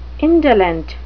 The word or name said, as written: indolent